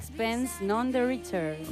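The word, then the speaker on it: Sixpence None the Richer: ¿Lo he dicho bien, hija? – Bastante bien. – Bastante bien. – Sí. – A ver cómo lo dirías tú. Sixpence. A ver, la anglófona. – Sixpence, none the richer.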